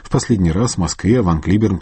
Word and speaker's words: Ван Клиберн: В среду в Соединенных Штатах скончался выдающийся американский пианист Ван Клиберн, как его называли в России. Правильный вариант произношения его имени – Ван Клиберн.